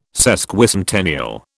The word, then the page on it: Sesquicentennial: British English